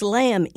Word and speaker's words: lamb: Why does lamb end in a B? You don't say lamb.